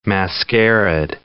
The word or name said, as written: mascaraed